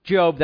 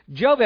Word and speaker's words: Job: We're reminded of Job here as a picture of the purpose of the Lord. Things didn't look good at all. If you don't know Job, that's how that's pronounced. It's not Job.